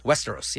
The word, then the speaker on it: Westeros: Okay, and this is it pronounced Westeros? – Westeros, yeah.